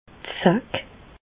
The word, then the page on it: d' Zack: Bas Rhin; Reichshoffen